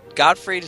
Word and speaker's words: Gottfried: Gottfried, is that how you say his name? – Yeah.